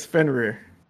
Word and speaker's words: Fenrir: How do you say it? – It's Fenrir. – Fenrir. So it's rear. Fenrir.